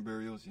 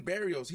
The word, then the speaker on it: Berrios: Burrows is out. Did I say his name right? The slot, the kid from the, that play in the slot that the Jets lost? – You talking about Braxton Berrios, yeah. – Braxton Berrios, he's gone.